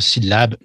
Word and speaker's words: syllabe: C'est-à-dire qu'en français, l'accent tonique est sur la dernière syllabe d'habitude. OK? Donc, par le mot syllabe, je veux dire syllabe, l'accent est sur le mot lab.